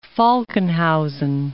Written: Falkenhausen

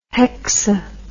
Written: hexa

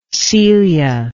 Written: celia

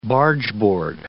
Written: bargeboard

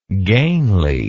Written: gainliness

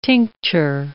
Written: tincture

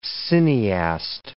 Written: cineaste

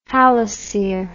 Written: halosere

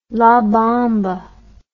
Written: la bamba

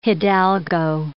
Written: hidalgo

